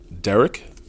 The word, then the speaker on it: Derek: Derek Geist. First name Derek is spelled the right way, D-E-R-E-K.